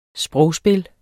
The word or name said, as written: sprogspil